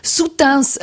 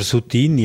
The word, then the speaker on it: Soutine: mit seinem Roman «Soutains letzte Fahrt». – Genau, der Chaim Soutain oder Soutine, je nachdem, wie man es aussprechen will, war ein russisch-jüdischer Maler.